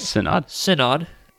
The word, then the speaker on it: synod: These bodies are the Evangelical Lutheran Church in America, the ELCA, the Lutheran Church Missouri Synod, did I say that right? – Synod, the LCMS, and the Wisconsin Evangelical Lutheran Synod, the WELS.